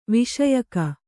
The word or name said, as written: ವೈಷಯಿಕ